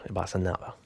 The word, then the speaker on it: Bossa Nova: 1963, that was Baden Powell with Improvisio and Bossa Nova, which is how they pronounce Bossa Nova, Bossa Nova.